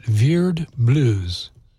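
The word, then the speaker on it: Vierd Blues: This is Veered Blues. You have to say it like that. Veered Blues.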